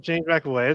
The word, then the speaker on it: McAvoy: Yeah, James Avoy. Is that how you pronounce the name? – Oh, he McAvoy? Oh, yeah, James McAvoy.